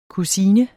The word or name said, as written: kusine